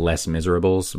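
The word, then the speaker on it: Les Misérables: It's spelled like Les Miserables, but it's French.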